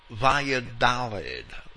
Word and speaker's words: Valladolid: Walsh continues, there is no doubt about the laxity of the monasteries of Seville and Valladolid would be the correct pronunciation,